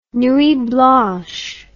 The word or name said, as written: nuit blanche